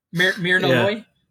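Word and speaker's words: Myrna Loy: I said it right? Mirna Loy? – Mirna Loy.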